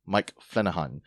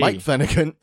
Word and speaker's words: Flanagan: or as I like to say it, Mike Flanahan. It's really Mike Flanagan, though.